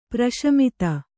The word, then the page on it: ಪ್ರಶಮಿತ: praśamita; Kannada